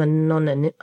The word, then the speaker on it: anonymity: Because, like you said, although you did have that fame, you still had some anonymity. Is that how you say it? – Anonymity. – That's it.